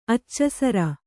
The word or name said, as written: ಅಚ್ಚಸರ